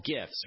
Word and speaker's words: GIF: They're called GIFs, right? G-I-F-S. Or is it pronounced GIFs? Well,